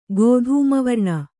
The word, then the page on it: ಗೋಧೂಮವರ್ಣ: Kannada